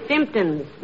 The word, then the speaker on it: Simpsons: The word is Simpsons, dearie, with the emphasis on the first syllable.